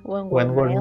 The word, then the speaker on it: Wentworth: Como ya mencionaste, Chris, eh, interpretado por Wentworth, ¿sí se pronuncia así? – Ajá, Wentworth, Miller, eh, a quien tengo en las cumbres por su interpretación de Leonard Snart en The Flash de mis favoritos.